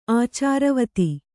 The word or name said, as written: ಆಚಾರವತಿ